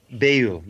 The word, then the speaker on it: Bayul: Is that pronounced Bayul? – Bayul, yes, literally.